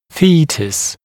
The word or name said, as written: foetus